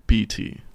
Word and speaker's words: GPT: I'm gonna say it wrong. Chat CPG. – You're so close. Is GPC. – So close. – GPC. – Nope. – Okay, what is it? – GPT.